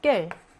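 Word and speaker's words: quel: 남성에서부터 여성 복수까지 형태가 깰. 남성 단수, 여성 단수. 지금 여성 단수죠, 깰. 남성 복수, 그다음에 여성 복수. 이렇게 나누어지게 됐죠. 형태가 이렇게 돼요. 네 가지의 모두 발음은 똑같습니다. 깰로 똑같아요. 하나로 통일이 됩니다.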